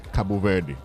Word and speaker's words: Cabo Verde: Cabo Verde. – That is the, and that's the right way.